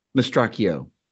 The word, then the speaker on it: Mastracchio: But if it has to be two, number one is Rick Mastracchio. Did I say that right? – Mastracchio.